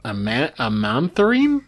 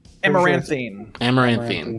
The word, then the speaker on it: Amaranthine: We are going to move on to SCP-7070 Amanthrine? Do you know how to pronounce this, Plague? – Amaranthine.